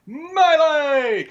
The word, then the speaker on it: Melee: You gotta listen to the announcer, Jason, because it's Super Smash Brothers Melee! – Come on, Melee!